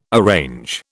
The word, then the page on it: arrange: British English